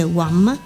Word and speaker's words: Wham: Wham, forse c'è una doppia V, si legge Wham all'inglese.